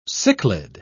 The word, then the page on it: cichlid: British English